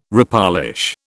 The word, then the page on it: repolish: British English